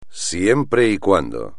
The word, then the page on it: siempre y cuando: espagnol